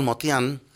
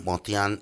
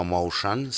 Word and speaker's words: Motian: Paul Motian, se dice así Motian y no Motion, ¿sí? Porque es armenio, Motian, Paul Motian, batería, murió hace un par de años, Paul Motian, de muchísimos 80 y muchísimos años.